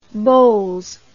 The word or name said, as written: Bols